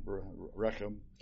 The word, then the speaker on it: Rechab: And David answered Rechab, and that's how it's pronounced, by the way, Rechab, and Baanah, his brother, the sons of Rimmon the Beerothite.